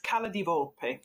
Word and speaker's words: Cala di Volpe: And you also recently released the coffee table book, Cala di Volpe. Am I saying that right? – Yes, Cala di Volpe.